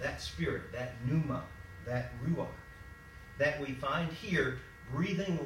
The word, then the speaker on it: ruach: The Hebrew equivalent to that concept, to that idea, to that word, the Hebrew equivalent, the Old Testament, is a word that's pronounced ruach, ruach.